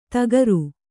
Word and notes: ಧಕಾರ: kannada

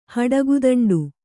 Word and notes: ಹಡಗುದಂಡು: kannada

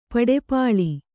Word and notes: ಪಡಪಾಳಿ: paḍapāḷi

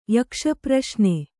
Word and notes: ಯಕ್ಷಪ್ರಶ್ನೆ: kannada

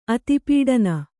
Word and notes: ಅತಿಪೀಡನ: Kannada

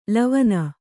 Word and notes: ಲವನ: lavana

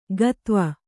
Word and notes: ಗತ್ವ: gatva

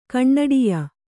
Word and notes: ಕಣ್ಣಡಿಯ: kaṇṇaḍiya

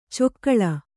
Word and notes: ಚೊಕ್ಕಳ: cokkaḷa

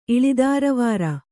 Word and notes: ಇಳಿದಾರವಾರ: iḷidāravāra

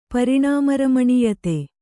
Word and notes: ಪರಿಣಾಮರಮಣೀಯತೆ: Kannada

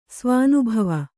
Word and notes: ಸ್ವಾನುಭವ: svānubhava

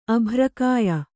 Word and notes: ಅಭ್ರಕಾಯೆ: Kannada

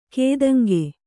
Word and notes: ಕೈತಾಂಗು: kannada

